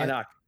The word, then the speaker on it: Anak: Oh, did I get that right? Anak. Is that how you say it? – Anak. It's Anak. Anak.